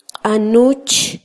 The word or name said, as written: qanuch